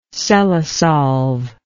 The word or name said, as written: cellosolve